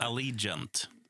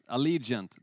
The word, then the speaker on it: Allegiant: Nej, jag kan inte uttala det. Allegiant. Allegiant, tack så mycket.